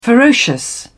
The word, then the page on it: ferocious: British English